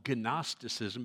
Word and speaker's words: Gnosticism: In the Greek, it's called Gnosticism because you always pronounce the G and the N together. Gnosis, the Greek word for knowledge.